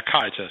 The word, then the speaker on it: Caritas: Er det rigtigt udtalt? – Ja, Caritas.